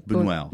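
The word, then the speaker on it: Buñuel: It's worth reminding listeners that uh, Boone, no, suddenly I'm not, saying it properly. – Boonwell. Boonwell, Uh, is a contemporary and friend of Salvador Dali and yeah.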